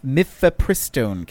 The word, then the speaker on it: mifepristone: Uh, the so the Supreme Court has granted a stay in the mif- mifepristone case. Mif- mifep- mifepristone. I cannot say I, that word. I think pharmaceuticals usually put the emphasis on the second syllable, but I'm not sure.